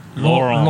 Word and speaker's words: Laurent: So, that was wrong. It's actually Laurent. – Laurent. – Laurent. Yeah, it's spelled L-A-U-R-E-N-T.